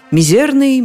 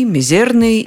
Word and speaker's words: мизерный: Чаще мы слышим это слово совсем с другим ударением – «мизерный». И словари эту тенденцию отражают. Так орфоэпический словарь под редакцией Аванесова хоть и рекомендует нам говорить «мизерный», но допускает и другое ударение «мизерный».